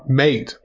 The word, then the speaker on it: MATE: So, it is mate. It's named after a South American drink or something.